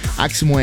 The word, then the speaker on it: Aximo: Aximo é A-X-I-M-O. Se escreve Aximo.